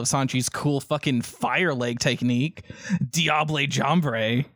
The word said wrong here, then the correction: Jambe: or I can't pronounce it. – Jambe.